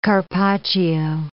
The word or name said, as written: carpaccio